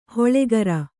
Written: ಹೊಳೆಗರ

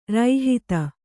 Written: ರೋಹಿತ

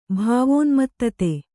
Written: ಭಾವೋನ್ಮತ್ತತೆ

